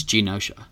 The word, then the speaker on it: Genosha: She became the zombie queen of a new, I can't pronounce the island, is Gonesha? – It's Genosha.